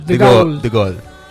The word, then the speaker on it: De Gaulle: ¿Cómo es la pronunciación de De Gaulle? – De Gaulle. Como el gol que vamos a hacer el sábado.